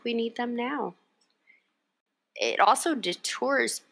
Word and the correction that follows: deters: Did I say that right? Detours people from booking with. – Deters. – Deters. Yeah. Deters people from booking with you.